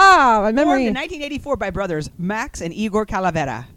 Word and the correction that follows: Cavalera: Oh no! Sorry. Cavalera. I said it wrong.